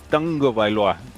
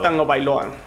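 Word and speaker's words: Tagovailoa: Es Tango Bailoa. Entonces, si soy no, raro, créanme, está estudiado. Tango Bailoa. – Él, mismo lo ha dicho, que así se pronuncia.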